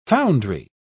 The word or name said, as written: foundry